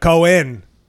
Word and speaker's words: Cohen: But Cohen, that's a little extra. Yeah, the extra Jewish syllable.